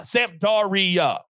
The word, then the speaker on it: Sampdoria: Sampdoria, four syllables, 245 Eastern Time.